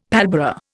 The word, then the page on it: pallbearer: Indian